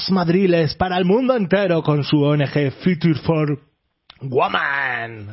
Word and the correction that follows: Future for Women: Lo he dicho mal, no me lo puedo creer. Nunca. Algún día lo conseguiré. Es el objetivo del 2025, va. Me marco eso. ¿Cómo se dice, Esther? A ver, por favor. – Future for Women.